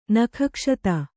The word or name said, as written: ನಖಕ್ಷತ